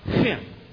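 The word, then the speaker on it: Hin (חן): You spell grace, H-N. And it's pronounced Hin.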